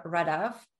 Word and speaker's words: Radav: So everything that I use on social media is Sandra Radav. So Sandra, S-A-N-D-R-A. And Radav is R-A-D-A-V.